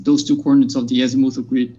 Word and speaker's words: azimuthal: He says, azimuthal. Am I saying that right? Have I been saying it wrong this whole time? – Azimuthal. – Azimuthal. Very embarrassing.